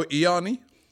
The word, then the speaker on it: Ianni: Candidate number two, Marco Ianni. Have I pronounced that right? – Yes.